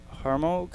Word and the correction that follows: Armaro: Vanessa Armaro, I'm sorry, I pronounced that incorrectly. Armaro is coming in.